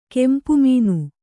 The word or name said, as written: ಕೆಂಪುಮೀನು